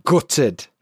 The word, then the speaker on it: gutted: Next is a word I use a lot, and it's gutted. Gutted. Now, for me, I glottalize the T. It's like gutted, but some people will pronounce the T gutted. Gutted. Doesn't matter which one.